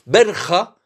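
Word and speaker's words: Borja: Berja, c'est la prononciation espagnole. Borgia, vous connaissez mieux, c'est la prononciation française et italienne.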